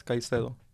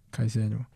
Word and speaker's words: Caicedo: I'll take Casado as well. – Who? – Moises. Casado. Oh. How do you say it in Spanish? – Moises Caicedo. – Caicedo.